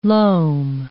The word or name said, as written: loam